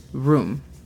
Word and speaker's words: room: Y la pronunciación fonética es R-U-M. – Room. – Perfecto. – ¿Se pronuncia igual que el ron, el licor? – No. – ¿No? El licor. – Porque el licor se escribe con M. Ajá. Y se pronuncia más como una A cuando dices el licor. Rum.